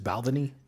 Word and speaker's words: Balvenie: I believe it's Balvenie.